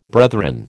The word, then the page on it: brethren: American English